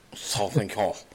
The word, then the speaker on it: Sauvignon: Sauvignon.